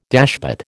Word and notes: dashpot: American English

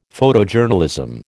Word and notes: Photojournalism: American English

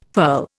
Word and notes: furl: Indian

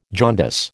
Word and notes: jaundice: American English

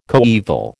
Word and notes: coeval: American English

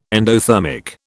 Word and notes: endothermic: British English

